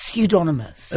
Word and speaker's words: pseudonymous: I think it's pseudonymous. – Is it? Okay, let's go with that. – I think we've found an extra syllable.